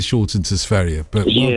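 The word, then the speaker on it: Sverige: No, Sveria is the right correct pronunciation. Well done, you, Ruby. Yeah, it's Sveria.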